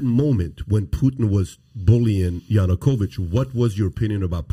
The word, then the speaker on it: Yanukovych: So in that situation, say it's 2013, and Yanukovych is sitting there, and he's kind of like, I'm not saying his name correctly. – Yanukovych